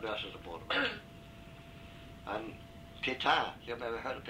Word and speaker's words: Pétain: It's spelled P-E-T-A-I-N, but it's pronounced Pétain.